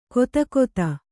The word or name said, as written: ಗದುಗದ